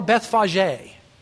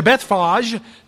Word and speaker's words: Bethphage: And when they had approached Jerusalem and come to Bethphage, or Bethphage, depending on your pronunciation, to Bethphage, to the Mount of Olives.